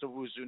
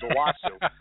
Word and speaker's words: Owusu: You know, I was able to get in Chris Owusu, which I know is pronounced Owusu now because I was corrected several times. Because I always thought it was Owusu.